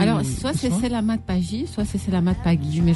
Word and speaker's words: Selamat Pagi: c'est Selamat Pagi Tangi, tu Non, non, c'est bien, c'est bien.